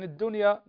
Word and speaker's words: الدنيا: نفس الشيء شوف يا محمد الفرق بين الحياة الفرق بين الحياة والدنيا اللام في الحياة عليها سكون فنقول الحياة لكن الدنيا نقول الدنيا فندغم اللام في الدال فلا تنطق اللام هنا.